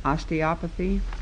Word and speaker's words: osteopathy: Amendment number 1 by Senator Poland, on page 1, line 13, after medicine, add osteopathy. Is that the way you pronounce it? – Yes, Senator Poland.